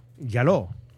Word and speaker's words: Jaló: Yaló.